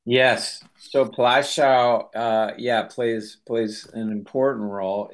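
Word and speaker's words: Płaszów: Plaszow, is that how you say it? – Plaszow. – Plaszow.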